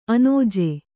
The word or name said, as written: ಅಣ್ಣಾಜಿ